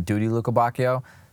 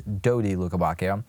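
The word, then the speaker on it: Dodi Lukébakio: Now, I know in previous episodes I've definitely called him Duty Lukebakio, so my bad for mispronouncing his name. And for any guys that are laughing at me, yeah, I'm sorry about that. His name is Dodi Lukebakio.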